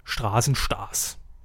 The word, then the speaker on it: Straßenstars: Stars mit SCH. – Ja, da liegt die Betonung drauf. Äh, Roberto, nee, wie heißt da noch der Moderator? Capelluti, ich glaube so, sagt ganz explizit Straßenstars. Willkommen bei Straßenstars.